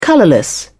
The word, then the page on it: colourless: British English